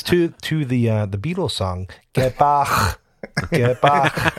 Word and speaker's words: Bach: Do you pronounce the first one the same way Paul does? He's like, Bach. He's really got the little back of the throat. – I assume it's the German. I don't. Is he German? – Bach. I do not pronounce it that way. It's just Bach.